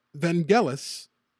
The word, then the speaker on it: Vangelis: And uh, Vangelis had a very interesting early life.